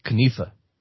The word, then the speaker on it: knife: So, like, the word knife used to be pronounced knife. You know, so, like, hand me that knife over there so I can cut my steak or whatever. And because of the French, the K became silent, and the I, instead of being E, became I.